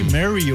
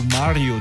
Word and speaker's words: Mario: E quem faz aniversário hoje, Mary ou Mario, dependendo como uhum. você quer pronunciar, é americano, né?